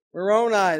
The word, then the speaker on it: Moroni: Moroni, there you go.